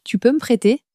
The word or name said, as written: tu peux me preter